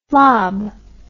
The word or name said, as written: flob